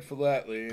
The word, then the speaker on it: philately: Is it pronounced philately? – Yeah, he said philately, and then he told what it was.